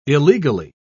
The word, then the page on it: illegally: British English